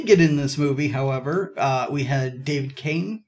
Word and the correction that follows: Kaye: I believe that, that's how you say his name. Uh, the the voice of Megatron, Beast Wars. David Kaye. K is K.